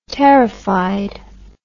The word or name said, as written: terrified